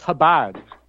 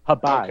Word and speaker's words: Chabad: Yeah, well, it's actually pronounced Chabad. Chabad.